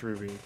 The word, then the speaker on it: RWBY: But I'm glad you didn't call it RWBY because I was gonna light your ass on fire. – Yeah, it's pronounced Ruby, but yeah.